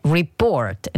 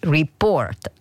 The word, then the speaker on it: report: che la pronuncia corretta di report è report, non report come ho detto prima io, quindi report, accento sulla O.